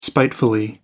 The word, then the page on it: spiteful: British English